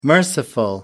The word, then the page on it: merciful: American English